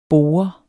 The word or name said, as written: bore